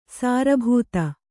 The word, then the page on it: ಸಾರಭೂತ: sāra bhūta